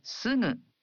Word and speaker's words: すぐ: It's すぐ a voiced nasal. In Japanese, you pronounce gu in the initial syllables of words as a voiced gu, like ga, gi, gu, ge, go. But for other syllables, such as sugu, you pronounce the gu sound as a nasal.